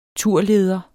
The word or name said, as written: turleder